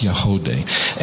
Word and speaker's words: Yagody: Y-A-G-O-D-Y, but it's pronounced Yahodeh.